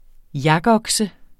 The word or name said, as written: yakokse